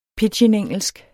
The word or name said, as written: pidginengelsk